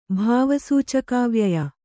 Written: ಭಾವಸೂಚಕಾವ್ಯಯ

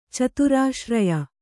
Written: ಚತುರಾಶ್ರಯ

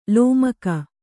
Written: ಲೋಮಕ

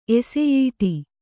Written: ಎಸೆಯೀಟಿ

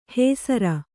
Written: ಹೇಸರ

